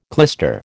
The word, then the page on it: clyster: American English